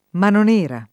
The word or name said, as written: manonera